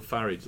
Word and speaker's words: Farage: Well, actually, doesn't Nigel say it's not Farage, it's Farage? Well, he says it's Farage like... – James as in garage. – James O'Brien pronounces it Farage, doesn't he? – Nigel Farage like garage.